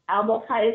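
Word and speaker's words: Albokai: Albok High is spelled A L B as in boy O K A I dot com.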